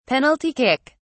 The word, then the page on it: penalty kick: British English